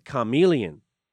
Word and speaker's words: chameleon: Janelle Monáe went as a chameleon. – She's a charmeleon, a chameleon. – Chameleon. – Chameleon. Okay. Sorry. The weird spelling.